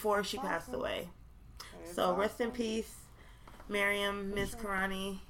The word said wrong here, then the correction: Mirzakhani: I feel like I always say her name wrong. Mirzakhani. It's not Miss Karani. Miriam Mirzakhani.